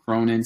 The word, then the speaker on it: Cronin: So if you go onto Facebook and you just type in Sean Cronin, my name, and you type in agent, my business page will pop up. So my name is spelled S-E-A-N, last name Cronin, C-R-O-N-I-N.